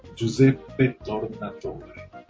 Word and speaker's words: Giuseppe Tornatore: Giuseppe Tornatore.